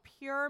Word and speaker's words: Pjur: It's called Pjur Med Intimate Toy Cleaner.